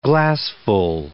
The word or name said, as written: glassful